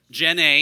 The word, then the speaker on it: Jenny: Jen A. Yes, you got the two-syllable pronunciation. Yeah.